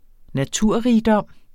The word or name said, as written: naturrigdom